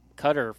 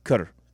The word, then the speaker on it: Qatar: Like, locally, I believe they pronounce it Qatar. But then here, most people say Qatar. – I say Qatar. – Like guitar? – Like guitar.